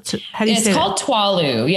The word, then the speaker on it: Tswalu: Tuwalu. Yeah. – Tuwalu. – It's spelled T-S-W-A-L-U.